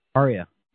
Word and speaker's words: Arya: The only other thing that you guys haven't beat to a pulp, in my opinion, was the youngest daughter, Aerys. Did I say that right? I'm sure I didn't. I'm horrible at pronunciation. – Arya, yeah.